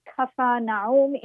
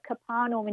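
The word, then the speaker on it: Capernaum: And this year, her film, which is pronounced Kafar Naum in Arabic, but Kafar Naum in English, won the jury prize at Cannes, and we're really proud.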